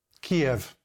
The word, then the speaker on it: Kiev: Kiev.